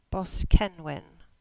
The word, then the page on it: Boskenwyn: English